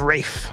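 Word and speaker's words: Ralph: Now, I always know that his name is pronounced weird. You know what I mean? But I never remember how to say it. So I had to look it up. – Is it Rafe? – It's Rafe.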